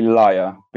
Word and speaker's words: lyre: No, I think it's actually lyre because I think it has to do with the actual pronunciation in Greek, which is lyre.